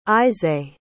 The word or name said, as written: isaye